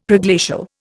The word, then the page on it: Preglacial: Indian